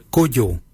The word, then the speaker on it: Goodjal: Ken is spelled G-E-N-Y, ken. Number 2, we say kujo. Kujo for number 2. Kujo is spelled G-O-O-D-J-A-L, kujo.